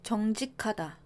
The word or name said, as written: 정직하다